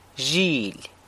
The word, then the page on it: zhily: sheep